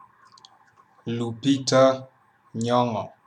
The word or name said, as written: Lupita Nyong'o